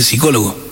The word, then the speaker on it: psicólogo: Discúlpeme, dice, se dice psicólogo, la P no se pronuncia.